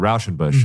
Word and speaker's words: Rauschenbusch: I don't know when the first usage of it was, but like Walter Rauschenbusch in the social gospel, that's like a full generation or two beyond Spurgeon's life.